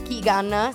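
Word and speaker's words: Keoghan: Ma oltre lui, anche Barry Keegan, sì. si pronuncia così, sì, no? Sì, sì esatto.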